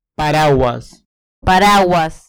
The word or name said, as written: paraguas